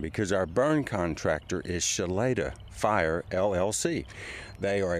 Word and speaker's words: Shloeta: Spelled S-H-L-O-E-T-A and pronounced Shalada.